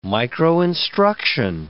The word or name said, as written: microinstruction